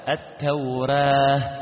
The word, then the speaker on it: التوراة: إذن التوراة تنطق هكذا، التوراة التوراة التوراة، الفتح التوراة، الإمالة الكبرى التوريه التوريه، الكسر التوري ري، وهذا لا يقرأ به، واضح؟